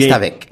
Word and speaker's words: restavèk: ou bien Reste avec.